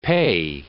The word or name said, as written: pe